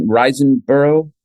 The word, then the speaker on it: Riseborough: It's a whole that's why this whole Andrea how do you say her Riseborough.